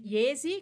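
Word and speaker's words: Jesi: Você tem Iesi, que é uma cidade italiana que começa com J, mas a pronúncia é I, eu não sei de onde veio esse J.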